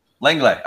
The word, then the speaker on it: Lenglet: And I felt like we were able to learn quite a lot about Lenglet. I'm going to continue to butcher the name here. Lenglet? – Lenglet.